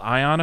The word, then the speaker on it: Aion: Then the other cool one was, so I was going by and there was this group called Ion Effects, which I had to look up how to pronounce mm-hmm. it correctly because it's spelled A-I-O-N.